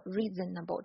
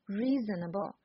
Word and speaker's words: reasonable: 美语发音呢，讲究的是这个连贯，像音乐一样，所以我们不能说 reasonable 这种东西，而是 reasonable。